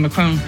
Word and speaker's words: Macron: And one quick note regarding Macron. And I know you're not supposed to pronounce the N, but I'm going for it.